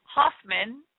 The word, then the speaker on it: Hoffman: T-O-N-Y-A, Hoffman, H-O-F, like Frank, M-A-N-N, two N's, .com.